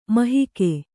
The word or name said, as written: ಮಹಿಕೆ